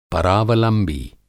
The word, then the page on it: परावलंबी: Hindi